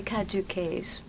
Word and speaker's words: caduceus: She said caduceus, but wouldn't we say caduceus? – Mm-hmm. Yes, that's a correct pronunciation.